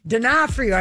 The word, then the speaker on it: D'Onofrio: I would call him D'Onofrio Frio. – Right, and, right. – Because it's D'Onofrio. I finally have it.